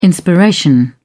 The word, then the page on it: inspiration: British English